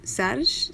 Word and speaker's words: سرج: The word for saddle, you say it like Sarge, Sarge. – Yeah, Sarge. – Sarge. It sounds like the name Serge that comes from Sergio.